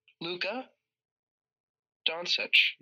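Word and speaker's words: Luka Dončić: Luka Doncic.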